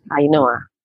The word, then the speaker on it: Ainhoa: Ainhoa. Perfectly, Ainhoa.